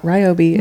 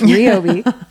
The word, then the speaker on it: Ryobi: And that is how you say it. It's not Ryobi. It's Ryobi. I work with them. It is Ryobi.